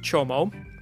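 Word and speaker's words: Csomó: So my last name is Hungarian, and everybody misspells it and everybody mispronounces it. It is pronounced Chomo.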